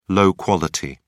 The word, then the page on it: low quality: British English